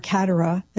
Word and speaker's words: Kateri: But eventually there would be priests that would come in, help form her in the faith, and she would be baptized Kateri, or Katara, as some pronounce it, named after the great Saint Catherine of Siena.